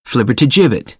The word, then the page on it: flibbertigibbet: American English; ɚ - ə